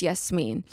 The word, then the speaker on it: Yasmin: And now when people say my name wrong, I'm like, actually, it's Yasmin.